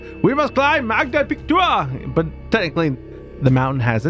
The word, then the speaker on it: Magda Pichtua: So, and I think that's a How do you spell that? – M-A-G-D-A, Magda, P-I-C-H-T-U-A. – Say that again? – Magda Pictua. – Pictua. – Pictua. – Pick to it.